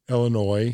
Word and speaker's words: Illinois: Illinois. And there's no S at the end. There is an S, but you don't pronounce it.